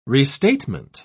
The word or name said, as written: restatement